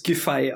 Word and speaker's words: Giffey: Ähm, laut Wikipedia spricht man es Giffey aus.